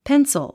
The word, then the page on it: pencil: péns ə l; ペンソゥ